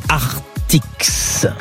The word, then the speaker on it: Artix: Et il est à Artix. On prononce bien le X de Artix, comme Astérix.